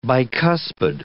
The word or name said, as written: bicuspid